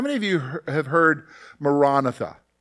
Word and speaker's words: Maranatha: Maranatha is the way you would pronounce it, and it means come, Lord Jesus, come.